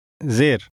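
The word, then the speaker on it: Zir (زیر): This mark is called a Zir. – This diacritic makes a shorter vowel sound, somewhat like the E in the word hen.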